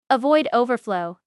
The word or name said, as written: Avoid overflow